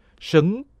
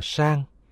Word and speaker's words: sấn: Đồ hiến cúng, chính văn là sấn, sấn cũng đọc là sang.